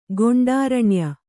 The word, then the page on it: ಗೊಂಡಾರಣ್ಯ: goṇḍāraṇya